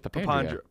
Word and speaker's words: Papandrea: I was going to say, I'm pretty sure it's Papandrea. – Papandrea. He said Pop and Dry. – It just sounds like you're saying two words. – He said technically it's pronounced Papandrea. Like, if you want to get technical and, like, Italian with it.